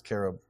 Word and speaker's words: cherub: Well, it's like everyone says cherub, but it's really supposed to be pronounced carob.